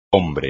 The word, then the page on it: hombre: espagnol